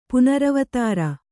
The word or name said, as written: ಪುನರವತಾರ